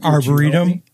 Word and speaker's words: arboretum: Arboretum.